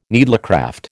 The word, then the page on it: needlecraft: American English